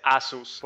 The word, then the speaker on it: Asus: Según yo, a ver, perdón, pero yo he pensado que siempre se dice Asus, porque no lleva tilde en la U. – Ah. – Y en inglés todas las palabras son graves.